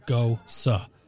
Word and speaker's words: Gose: And for those who never bothered to ask their bartender, it's pronounced Goza.